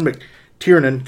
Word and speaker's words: McTiernan: Predator came out in 1987, was directed by John McTiernan. God, I hope I pronounced that right. – Yeah, you got it.